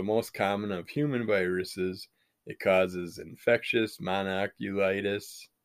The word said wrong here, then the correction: mononucleosis: mononucleosis, I think is how you say it, also called mono, and is a known risk factor for MS.